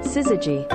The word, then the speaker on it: syzygy: Syzygy.